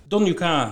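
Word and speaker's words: Donnacha: Ja, Don U.K. zo spreek je het uit, O'Brien, dat is een jongste zoon.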